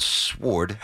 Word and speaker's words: sword: For example, in the word sword, you don't pronounce the letter W, but you are not technically wrong if you do.